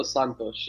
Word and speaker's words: Santos: Because if you're Fernando Sanches, um, did I say that right? Did I say uh, you're you're muted? – Sorry about that. Sorry. – Yeah. did I did I say it right? Fernando Santos. Yes, that's correct. Santos.